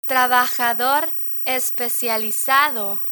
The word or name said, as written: trabajador especializado